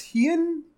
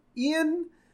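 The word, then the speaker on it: Heian: Uh, scene skip to the host club conducting business outdoors, and uh, I never know how to pronounce this. Hien, Ian, era garb. It's like old Japanese garb. Yeah, I think Hien's right.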